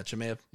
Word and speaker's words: Chimaev: Yeah, like the guy Gilbert Burns, he fought how do you even say it? Chimaev? – Yeah, Chimaev.